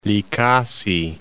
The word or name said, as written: Likasi